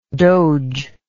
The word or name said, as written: dogeship